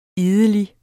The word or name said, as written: idelig